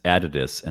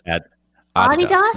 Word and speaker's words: Adidas: That's the way it is correctly pronounced. It's Adidas, not Adidas. It's a German name. – Okay, you're saying Adidas. – Yeah, and it's spelled Adidas. N-I-K-E.